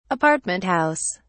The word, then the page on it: apartment house: British English